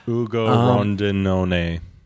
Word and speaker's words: Ugo Rondinone: Ugo Rondinone.